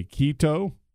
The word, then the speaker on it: Quito: So another tournament that's going on, another 250, is in, I guess you pronounce like a K, Quito? – Yeah, that sounds right.